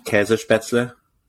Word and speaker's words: Käsespätzle: Käsespätzle.